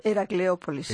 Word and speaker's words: Heracleópolis: Desde 1984 dirige la excavación de Heracópolis, lo he dicho bien. – Heracleópolis. – Heracleópolis, Jesús.